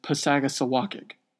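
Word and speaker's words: Passagassawakeag: The river in my hometown is pronounced Passagasawakig. I didn't learn how to say that correctly until I was 17.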